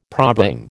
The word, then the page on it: probang: American English